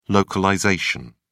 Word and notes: localisation: British English

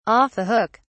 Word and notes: off the hook: British English

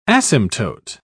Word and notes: asymptote: British English

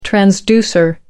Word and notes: transducer: American English

